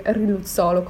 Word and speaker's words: riluzolo: Credo che sia infatti riluzolo, come l'ha detto lei.